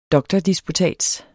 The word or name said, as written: DOKTORDISPUTATSERNE